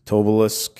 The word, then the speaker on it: Tobolsk: Um, present day city, if you want to check it out, is Tobolsk. I think I'm saying that right. T O B O L S K.